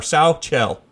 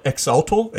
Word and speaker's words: Xochitl: I looked it up today because it's spelled Xochitl. So, and I've looked on many sites. It's So or Sauchel. – Sauchel. – Sauchel. – Okay. – So, yeah. I was going to call it Exaltal.